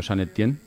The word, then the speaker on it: Saint-Étienne: ¿Has visto que bien pronuncio Saint-Étienne? – Sí, y Francia también, lo has dicho bastante bien.